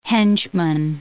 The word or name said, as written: henchman